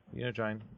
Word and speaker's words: Unigine: Yeah, Unigine. U-N-I-G-I-N-E.